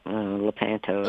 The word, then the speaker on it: Lepanto: And you were born in Lepanto, Arkansas. Correct me if I pronounce that wrong. But Lepanto, that's close enough.